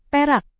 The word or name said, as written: perak